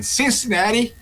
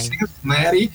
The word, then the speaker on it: Cincinnati: Estou vendo a expectativa aí do João Fonseca, nosso melhor tenista, que vai estrear no Master 1000, dessa vez lá em Cincinnati, nos Estados Unidos, né? Pronúncia perfeita. – Ótimo, no inglês. Você está muito bem. – Cincinnati.